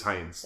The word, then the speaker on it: Hinds: Is that the correct pronunciation? I thought it was... – H-A-F-A-N-T-I-N-D-Z. I believe it is Hines. – Okay. But it's not spelled that way. – It is spelled H-I-N-D-S, so yeah.